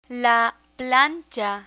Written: plancha

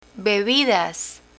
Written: bebidas

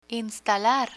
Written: instalar

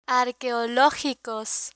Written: arqueologicos

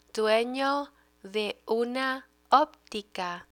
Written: dueno de una optica

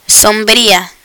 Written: sombrilla

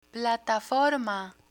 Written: plataforma

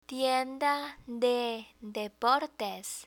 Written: tienda de deportes

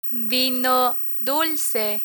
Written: vino dulce

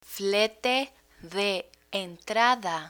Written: flete de entrada

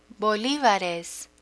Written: bolivares

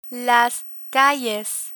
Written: calles

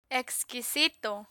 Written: exquisito

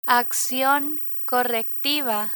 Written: accion correctiva

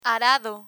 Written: arado

